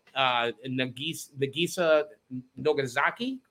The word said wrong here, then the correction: Nagisa Nozaki: Did I say that right? – Nozaki? – Yeah, Nagisa Nozaki.